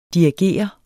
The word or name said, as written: dirigere